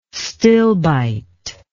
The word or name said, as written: stilbite